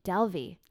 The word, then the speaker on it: Dalvi: The pronunciation is incorrect. – Oh, I'm so sorry. Tell me. – It's Aashay. Aashay. Dalvi.